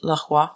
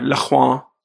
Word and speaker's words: LaCroix: That's pronounced LaCroix. – Sorry, LaCroix.